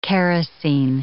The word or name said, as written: kerosene